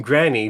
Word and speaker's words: Grani: And I'm saying Granny, but it's spelled Grani, because that's her name.